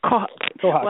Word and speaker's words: kohaku: If you're looking for a kohaku, and am I saying that right, kohaku, or is it kohaku? – Kohaku. Well, kohaku.